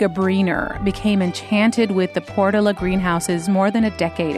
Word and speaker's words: Portola: And if you're not from around here, you might not know that that's how some locals pronounce it, Portola.